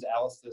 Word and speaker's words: Alisdair: So if I'm giving you 1.5 seconds and my name's Alistair,